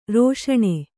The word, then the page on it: ರೇಷಣ: rēṣaṇa